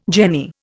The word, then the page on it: jenny: Indian